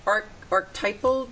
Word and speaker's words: archetypal: Before we go to break, the archetypal dreams, am I saying that correctly? – Archetypal, yeah, that's it, yeah.